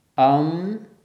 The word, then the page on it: aṃ: sanskrit